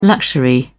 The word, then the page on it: luxury: British English